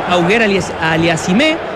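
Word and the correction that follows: Auger-Aliassime: Es Oyer Aliassime, el canadiense. Félix Oyer Aliassime.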